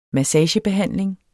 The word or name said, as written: massagebehandling